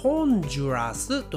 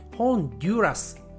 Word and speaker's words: ホンジュラス: こちらの国は日本語の発音ではホンジュラスと言います英語の発音ですと DU が入っているのでホンデュラスねそのように聞こえますが日本人はホンジュラスと発音します